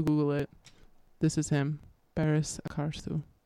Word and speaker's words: Barış Akarsu: Next up, we're going to listen to Bir Sevmek Bin Dafa Olmek Demits, long song, by the artist Barış Akarsu.